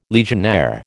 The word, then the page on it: Legionnaire: American English